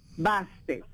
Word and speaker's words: Bastet: Basket. – Bastet. – ¿Cómo se escribe? – B-A-Bastet. – Bastet. – Bastet. – B-A-S-T-E-T, Bastet.